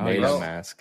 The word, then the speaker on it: MetaMask: Oh, MetaMask.